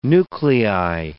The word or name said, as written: nuclei